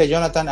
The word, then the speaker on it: Jonathan: Mi nombre es Jonathan. La mayoría de acá en Latinoamérica usamos tantos diminutivos que casi todos me decían Jona. Y como mi nombre se escribe Jonathan, pero se pronuncia Jonathan, había varios amigos que me decían Jona.